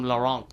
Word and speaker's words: Laurent: And of course, now with the fourth arc, mm-hmm, is now mostly focused on Laurent. Yes. And that's how you pronounce the name, by the way. You know, given the English dub has come out, so yeah. – Oh, really? – Yeah. The English dub calls him Laurent because he's French.